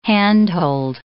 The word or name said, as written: handhold